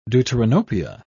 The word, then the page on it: deuteranopia: British English